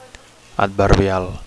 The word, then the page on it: adverbial: Catalan